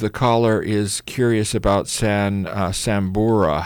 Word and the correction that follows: Sambucol: I pronounced it Sambucol. – Sambucol. – S-A-M-B-U-C-O-L.